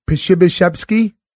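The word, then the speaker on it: Przybyszewski: What's his last name and how's that properly pronounced? – Pshibyshevsky. – With a V. Pshibyshevsky.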